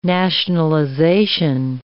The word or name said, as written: nationalization